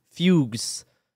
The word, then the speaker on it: fugue: In some cases, terrifying visions were followed by fugus, which is an old term for, like, fugues. It says fugues. – Yeah, fugue. It's pronounced fugue.